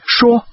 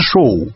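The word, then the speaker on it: Shaw: Вот писатель, которого фамилия по-английски «Шо», по-русски произносится «Шоу». А писатель, которого фамилия «Поу», произносится «По».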